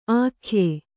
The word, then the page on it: ಆಖ್ಯೆ: ākhye